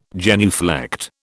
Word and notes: genuflect: British English

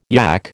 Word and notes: yak: American English

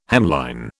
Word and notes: hemline: British English

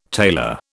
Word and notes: tailor: British English

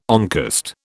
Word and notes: oncost: British English

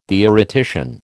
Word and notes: theoretician: American English

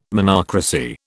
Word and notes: monocracy: British English

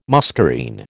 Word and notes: muscarine: American English

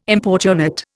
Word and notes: importunate: Indian

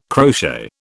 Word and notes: crochet: British English

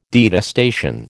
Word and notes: detestation: American English

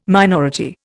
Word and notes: minority: Indian

